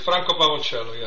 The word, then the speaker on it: Franco Pavoncello: Franco Pavoncello, yes. – Actually, that sounded different from the way I did it. That sounded better.